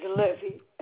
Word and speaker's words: Levy: First name is Levy. L-E-V-Y. – L-E-V-Y?